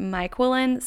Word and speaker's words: Myquillyn: So one resource I look to in this arena of intentional homemaking are literally all three books by the author named Myquillen Smith. Her name is spelled M-Y-Q-U-I-L-L-Y-N. She says like Jacqueline and Michael put together.